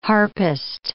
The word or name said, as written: harpist